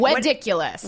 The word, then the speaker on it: Weddiculous: Weddiculous.